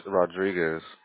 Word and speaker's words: Rodriguez: That's R-I-C-A-R-D-O. – Okay, can you help me with your last name, Ricardo? – Yes, it's Rodriguez. – R-O-D-R-I-G-U-E-Z? – Correct.